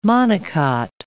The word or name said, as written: monocot